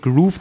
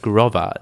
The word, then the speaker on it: Grover: Das hat nichts mit Groove zu tun. Haben wir Mails und Tweets bekommen. Das ist Grover. So sprechen sich die wohl aus.